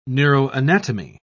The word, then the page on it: neuroanatomy: British English